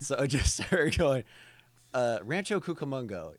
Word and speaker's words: Rancho Cucamonga: the section where she was trying to say Rancho Cucamonga? – Yes.